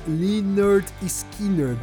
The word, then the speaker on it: Lynyrd Skynyrd: Eles mudaram o nome pra banda de Leonard Skinner que eu fiquei muito tempo sem saber pronunciar o nome da banda, porque é um, um nome bem estranho mesmo quando você olha assim. Então, depois de muito tempo eu fui saber que era Leonard Skinner, mas que, que nome estranho é esse?